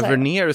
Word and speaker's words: Tavernier: Jag uttalar Marcus Tavernier. Är det så man säger? – Tavernier skulle jag säga, men du ska absolut inte lita på min.